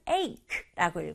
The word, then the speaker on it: ache: ache는 ache라고 읽어요.